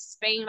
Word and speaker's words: Spain: My last name is Spain like the country.